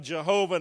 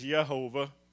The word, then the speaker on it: Yehovah: But I want to talk about the Jehovah names of God. Actually, the, the, correct way to say that, we say Jehovah, but it's Yehovah. If you want to speak Hebrew, it's Yehovah.